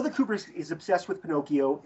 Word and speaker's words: Pinocchio: But all this pronounces Pinocchio in the correct way, which is Pinocchio, okay? Which is, that's how you'd say it in Italian, Pinocchio.